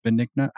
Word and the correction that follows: Bengio: Yasha Bengio.